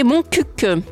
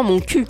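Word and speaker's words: Montcuq: Alors, il faut dire que là-bas, on peut se mettre à poil à moindre frais, puisque le nom du village se prononce en réalité Moncuc. Alors que l'on prononce volontairement mon cul, pour faire rire.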